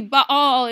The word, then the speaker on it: Baal: Baal is how you say that name.